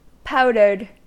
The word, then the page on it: powdered: American English